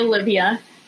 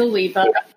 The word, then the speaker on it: Oliva: A lot of people like to say Olivia yep. versus Oliva.